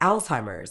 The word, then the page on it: Alzheimer’s: British English